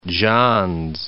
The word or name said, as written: Johns